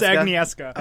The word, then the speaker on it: Agnieszka: Agnieszka. – I don't know how you say her name. – I know how to pronounce that, but that's it. Agnieszka? I know it's Agnieszka.